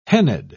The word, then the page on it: hennaed: British English